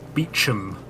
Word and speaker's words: Beauchamp: So Francis sees someone he recognises from more recent times, a Rupert Beecham, although it's spelled Beauchamp.